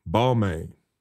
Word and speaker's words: Balmain: Balmain.